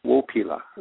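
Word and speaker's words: wópila: It starts with the letter W. If you can say wo. – Wo? – Can you say wo? Yeah. And the second wo. – Syllable is pila. Wo pila. – Wo pila. – I guess three syllables, yeah. Wo pila. And the accent. Yeah. The accent is in the first syllable. Wo pila.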